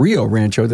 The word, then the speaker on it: Rio Rancho: And Dustin Brown from Brown Auto Experts, 3 locations in New Mexico, Albuquerque, in the village of Los Ranchos, and Rio Rancho. Did I say that right? – That's it.